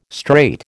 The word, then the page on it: straight: American English